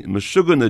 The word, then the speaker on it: Meshuggah: So if I can begin by misquoting Tolstoy, every Jewish family is alike, but every Meshuggah Jewish family is Meshuggah in their own way. – You're not Jewish, but you've done very well with the pronunciation there.